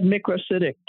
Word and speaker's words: microcytic: I had a professor that said microcytic too. It's the British way to pronounce it.